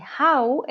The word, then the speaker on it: hau: Lucrecia dice Gau, au, con sonido H, hau, en Polonia.